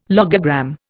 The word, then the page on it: logogram: Indian